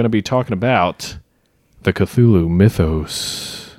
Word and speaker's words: Cthulhu: The one that we are using, Cthulhu, yeah. is sort of the standard, it's like sort of the Protestant version nice.